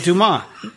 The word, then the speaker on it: Dumas: Yeah, that's what I said, Dumas. You thought I said Dafoe. Listen to my, I'm enunciating my syllables. Dumas. Okay. It's spelled like dumbass with no B.